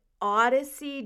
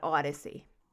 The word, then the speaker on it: Audyssie: Odyssey James, kind of like a mashup between Audrey and Odyssey, because it's A-U-D-Y-S-S-I-E.